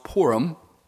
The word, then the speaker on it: Purim: It's actually pronounced Purim, and it marks the deliverance of the Jews through the Jewish Queen Esther in Shushan, Persia.